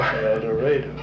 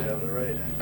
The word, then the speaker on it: El Dorado: You know, most people say, you sure it's not El Dorado? And no, El Dorado. – El Dorado, that's right. – What the natives call it. – Most people in this state pronounce it El Dorado.